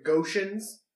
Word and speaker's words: Ghotians: How you would theoretically read this is like Goshens. Like, you know, like Martians.